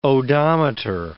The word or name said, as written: odometer